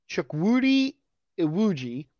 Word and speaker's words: Chukwudi Iwuji: It included actor Chukwudi Iwuji. I said that right because I Googled it ahead of time.